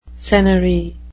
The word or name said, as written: senary